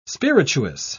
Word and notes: spirituous: British English

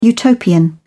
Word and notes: utopian: British English

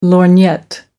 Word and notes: lorgnette: American English

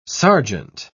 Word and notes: serjeant: British English

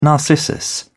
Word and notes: narcissus: British English

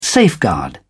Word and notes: safeguard: British English

